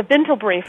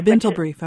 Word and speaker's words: Bintel Brief: Yes, the Bintel Brief. – The Bintel Brief, okay.